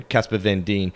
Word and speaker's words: Casper Van Dien: Actually, that's one thing I do want to say about Casper Van Niem or however you say his name. He has Van Dien, sorry, Casper Van Dien.